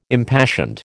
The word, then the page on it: impassioned: American English